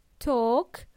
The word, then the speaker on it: talk: Talk has a silent L. We pronounce it as talk, not talk. Talk, small talk.